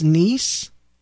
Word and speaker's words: Nies: I used to call them the Nies family because it's spelled N-I-E-S, but apparently it's pronounced Nice.